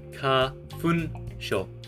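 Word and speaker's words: Kafunsho: But don't pronounce it like that, not in that Aussie accent, that's terrible. Pronounce it ka-fun-sho.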